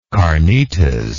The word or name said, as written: carnitas